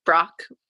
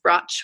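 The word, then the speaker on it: Brač: It's on the island of Brock. It's actually pronounced Broch, which is B R A C.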